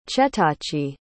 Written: chetachi

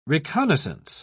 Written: reconnaissance

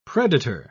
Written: predator

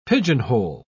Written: pigeonhole

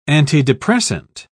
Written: antidepressant

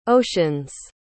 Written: oceans

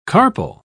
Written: carpel

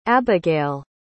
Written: abygale

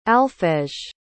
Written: alphege

